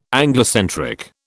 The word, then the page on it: Anglocentric: British English